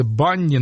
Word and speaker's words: BNNY: Там живет девушка по имени Джессика Вишиус, которая вместе со своей сестрой и подругами образовала ансамблик под названием, ну, я думаю, что это читается Bunny, но почему-то буква U в названии везде пропущена, то есть B-N-N-Y.